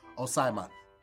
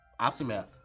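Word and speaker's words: opsimath: Opsymath. – That's closer, yes. The O is like an ah.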